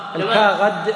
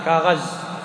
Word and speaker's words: الكاغد: الكاغد بالدال المهملة، هكذا ينطقها العرب، وهي فارسية معربة، وأهل فارس ينطقونها بالذال كاغز.